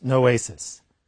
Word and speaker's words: noesis: The Greek is pronounced noesis.